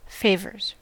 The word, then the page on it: favors: American English